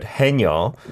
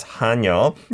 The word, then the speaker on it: 하녀: We've got to be careful with the pronunciation there because many of you have heard the word 해녀. That's not what we're saying there. It's not a woman diver. It's 하녀. And we'll go into details later.